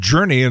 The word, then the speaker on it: JRNY: It's called Journey, and it's spelled J-R-N-Y.